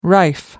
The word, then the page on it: rife: American English